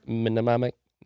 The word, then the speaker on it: mnemonic: Mnemonic? – Yeah. – The M is silent, right? You just say mnemonic.